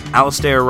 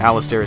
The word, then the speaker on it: Alasdair: Before that, Alasdair Roberts, a song called Hazel Forks off his album Spoils.